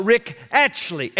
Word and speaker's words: Rick Atchley: And I said, Yes, just ask him to call Rick Atchley.